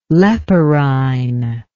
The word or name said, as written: leporine